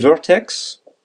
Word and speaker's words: Vert.x: Donc, euh, donc euh, au niveau prononciation, je peux en dire Vertex.